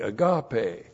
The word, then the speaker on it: agape: A-G-A-P, long E, agape.